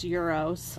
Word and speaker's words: gyro: Rice pilaf, Greek salad, and a gyro sandwich. – Gyro. – Which is spelled G-Y-R-O, so a lot of people call them gyros with their... pronounced euros.